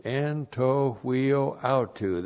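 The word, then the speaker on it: Anto huio autu (ἐν τῷ υἱῷ αὐτοῦ): En to huio auto. And that's E-N-T. This is the dative case of the article, T long O. And then H-U-I long O. And then A-U-T-O-U. Anto huio autu.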